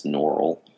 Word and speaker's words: Norrell: I think it's Mr. Norrell, because I watched the BBC series and they pronounced it as Norrell.